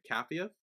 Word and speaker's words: Capheus: Did I say his name right? Then because that was why I was questioning. Cappius. – Yes. Yeah, C A P H E U S. Cappius. – C H P H. – C C A. – C A P H. – P H E U S. – E U S. So Cappius.